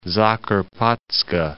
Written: Zakarpats'ka

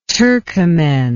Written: turkomen

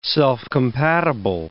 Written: self-compatible

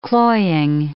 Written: cloying

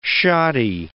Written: shoddy

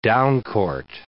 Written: downcourt

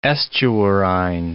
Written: estuarine

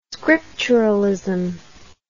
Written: scripturalism

